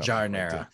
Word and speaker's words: giardiniera: So giardiniera.